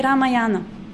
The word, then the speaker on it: Ramayana: Many devotees say Ramayana. Other mispronunciations, if you have heard, you can tell us so that we don't do them. Narayan is another word, yeah. So remember, Ramayana, not Ramayana.